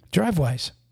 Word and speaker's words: Drivewyze: And last but not least, we are brought to you by DriveWise. And that's spelled DriveWyze.